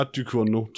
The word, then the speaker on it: Ducournau: Julia Ducournau, tror jeg, ville være den rette udtale.